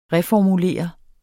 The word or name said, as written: reformulere